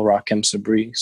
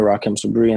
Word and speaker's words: Rahkim Sabree: My Instagram, my Twitter is my name as well, Rakim Sabri. So it's spelled RahkimSabree. So that's Instagram and Twitter, Rakim Sabri.